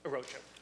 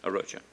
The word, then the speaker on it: Arocha: Did I pronounce that correctly? – Arocha. – Arocha, yeah.